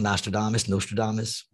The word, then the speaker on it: Nostradamus: So what I think is going to happen is, and I could be wrong, of course, I'm no Nostradamus. No, I'm just looking at the shit that I see. What did I say it wrong? Nostradamus, whatever.